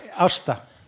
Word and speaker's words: Ásta: You can pronounce it as you will, but but I pronounce it a bit different. Uh, Tell me, please help me. Osta. Osta. Osta.